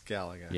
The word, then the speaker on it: Galaga: It's Galaga.